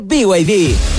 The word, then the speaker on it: BYD: Se escribe B&B, pero se dice BYD.